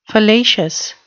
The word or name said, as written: fallacious